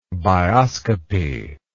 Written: bioscopic

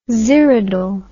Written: xyridal

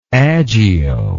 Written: agio

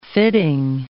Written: fitting

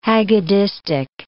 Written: haggadistic

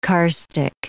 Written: karstic

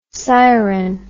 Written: syren

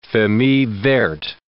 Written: famille verte

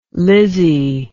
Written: Lizzy

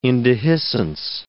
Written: indehiscence

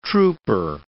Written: trouper

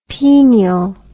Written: penial

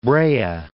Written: Brea

